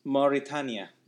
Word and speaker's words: Mauritania: Iran, Saudi Arabia, Sudan, Yemen, and Mauritania. Is that how you say it? I don't even know where the hell country that is. – Mauritania.